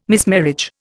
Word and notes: mismarriage: Indian

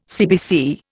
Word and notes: CBC: Indian

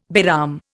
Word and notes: Bairam: Indian

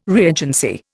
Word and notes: reagency: Indian

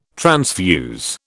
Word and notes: transfuse: British English

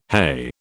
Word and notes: heigh: British English

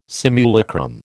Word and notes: simulacrum: American English

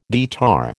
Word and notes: guitar: American English

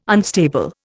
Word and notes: unstable: Indian

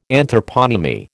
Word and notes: Anthroponymy: American English

